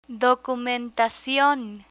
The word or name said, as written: documentacion